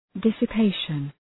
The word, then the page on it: dissipation: ,dısə’peıʃən